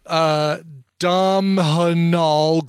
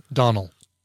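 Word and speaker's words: Domhnall: Please pronounce Dom Hanal Gleason's name correctly is pronounced the same as Donald. – Yeah, it's Donald Gleason, and I'm completely guilty of trying to pronounce every single letter in his name.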